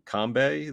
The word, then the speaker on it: Kanbei: Kanbei? Is that how you pronounce that? – Yeah.